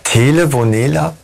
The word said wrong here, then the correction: Telenovela: Telenovela für Arme.